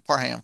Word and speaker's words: Parham: He pronounces it H-A-M like a ham. Parham.